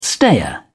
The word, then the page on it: stayer: British English